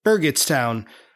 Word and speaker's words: Burgettstown: First of all, throughout the episode, I incorrectly pronounced the name of the town as Burgettstown, while Pete accurately calls it Burgettstown, with a hard G.